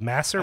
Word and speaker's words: Masur: Now, how do you say his last name? Masser? – I think, Ma- I think it's Masser. Masser. Masser. Yeah.